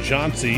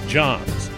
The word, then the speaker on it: John C.: On one last note, there's a debate on how the name John C. is pronounced, with some pronouncing it John's.